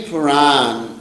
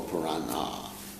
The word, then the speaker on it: Purana: Well, any Puran or Purana, these are the correct pronunciation.